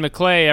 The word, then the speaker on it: McClay: Please welcome Ben McClay, everybody. Ben McClay. – Hey. Yeah. – And that even and his name and his name rhymes with g'day.